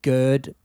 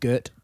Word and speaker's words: girt: He was worried that he was giving us crap that we said it sounded like we said gird by C, not girt by C.